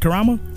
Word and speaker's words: Karama: Is that, how does he pronounce that? Was it Karma or Camera? – It looks like Karama? Divine Karama? – Yeah, Karama. – Okay, Divine Karama.